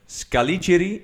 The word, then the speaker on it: Scaligeri: Eh, dat is waar de Scaligeri, zeg ik het goed? – Heel goed De Scaligeri-familie uh, eh, eh, lang gewoond heeft.